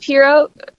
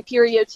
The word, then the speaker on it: Perriot: It's pronounced Piro is the proper pronunciation. I used to say Perio too.